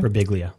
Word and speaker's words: Birbiglia: It has Mike Birbiglia, I think is how you say his last name. – Birbiglia. – Yeah.